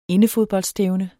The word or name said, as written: indefodboldstævne